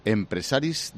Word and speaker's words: Empresaris: Y nos hemos encontrado y estamos charlando aquí, dando un paseíto, con don Josep Bou, que es el presidente de Empresaris de Cataluña. ¿Lo he dicho bien? – Sí, muy bien. Empresaris de Cataluña, lo decimos en catalán porque somos catalanes.